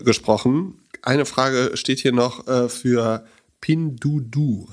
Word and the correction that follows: Pinduoduo: Habe ich das richtig ausgesprochen? – Ich glaube, Pindodo ist die richtige Aussprache.